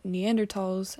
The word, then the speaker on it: Neanderthals: Like, if you're looking at Neanderthals, and, like, that's how you say it, by the way, not thals,